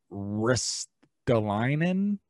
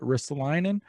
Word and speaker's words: Ristolainen: Also, Ristolainen, I believe is how you say it, Ristolainen, he's asked for a trade out too.